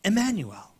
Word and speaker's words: Emmanuel: So in Hebrew, Greek, and English, it's all pronounced pretty much the same, Emmanuel.